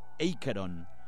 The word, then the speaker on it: Acheron: Bueno, pues ha visto cómo pronuncia en la canción Bells of Asheron, él dice Acheron, Bells of Acheron.